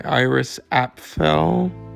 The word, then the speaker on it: Iris Apfel: and also famous for the documentary Iris about how do you say her name? Because she's still with us. She's 100. And she's a fashion... Iris Eiffel? Let me see. Documentary. Let me see. Yeah, Iris Apfel.